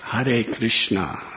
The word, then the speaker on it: Hare Krishna: I said, it's pronounced Hare Krishna.